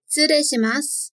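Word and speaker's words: 失礼します: Só lembra de tomar cuidado com esse tsu, né? Que não é bem pronunciado o shitsureshimasu. Além disso, o re aqui, né? Não é lido como rei, mas re, prolongado. Então, fala depois de mim.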